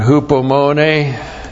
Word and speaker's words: hupomone: 1st virtue, virtue number 1, hupomone. H-U-P-O-M-O-N, long E, hupomone.